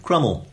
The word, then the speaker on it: Cromwell: And incidentally, gave me another clue to the pronunciation of Crummel. – Right, okay. – Because on more or less day one of the rebellion, some rebels in Dent shouted that we would crumb him and crumb him till he was never so crumbed.